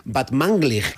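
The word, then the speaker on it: Batmanglij: Batmanglig, creo que es ese.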